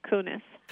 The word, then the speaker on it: Koones: So I've mispronounced your name all the way through the interview, and it's spelled K-O-O-N-E-S. Is that right? – Correct? – That's right. And it's Sherry Kunis.